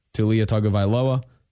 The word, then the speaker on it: Tua Tagovailoa: Tua Tagovailoa.